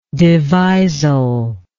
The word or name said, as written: devisal